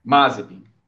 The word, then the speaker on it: Mazepin: Ou Mazepin, não entendo, não sei a pronúncia ainda. – Mazepin. – É certo.